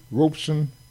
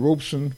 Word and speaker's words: Robeson: In 1933, a magazine wrote asking Robeson for the correct pronunciation of his last name. He said his name is robe, as the ordinary word robe, meaning dress, and son, pronounced like the word son.